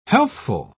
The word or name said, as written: healthful